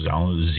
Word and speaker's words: Zhou: Uh, it's first recorded in China during the Western Zhou dynasty. I think that's how it's pronounced Zhou, Z H O U dynasty, which was 1040 uh, to 770 BC, so quite a long span there.